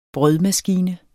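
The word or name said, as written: brødmaskine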